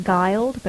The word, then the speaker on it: Guild: And my name being spelled is pronounced Guild, but it looks like Guild, G-U-I-L-D.